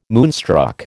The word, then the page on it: moonstruck: American English